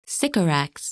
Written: Sycorax

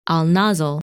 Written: Alnasl

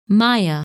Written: Maia

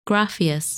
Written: Graffias

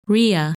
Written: Rhea

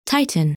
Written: Titan

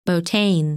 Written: Botein

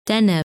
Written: Deneb

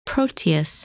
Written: Proteus

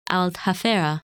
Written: Aldhafera

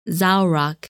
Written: Zaurak

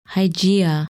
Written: Hygiea

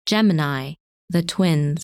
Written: Gemini

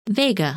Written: Vega

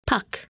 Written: Puck

